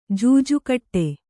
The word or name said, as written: ಜೂಜುಕಟ್ಟು